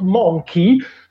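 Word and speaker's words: Mynci: E, dunque, volevo fare una precisazione che in realtà ho scoperto anch'io di recente, che in realtà il nome del gruppo si pronuncerebbe Gorky's Zygotic Monkey, perché hanno preso la parola monkey ma l'hanno scritta come si scriverebbe secondo lo spelling del gallese,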